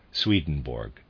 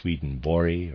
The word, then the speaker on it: Swedenborg: It's entitled Jakob Boehme, Paracelsus, and I'm going to pronounce it this way, Swedenborg. It's properly pronounced Swedenborg or something to that effect, but I will be pronouncing it Swedenborg.